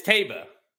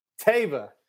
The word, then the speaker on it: Taiba: No, he said he or she who texted me said they hated the hat, but they did confirm that it's Taiba Frank Miramonte got the pronunciation directly from the owner. – Oh, hey, I got the same. Don't act like you're so cool. I got it too. Okay. And you know what, John? Thanks for watching, buddy. It's Taba.